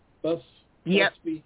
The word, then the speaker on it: Busby: Am I saying that right, Busby? – Busby. – Okay. – B-U-S-B-Y, that's me.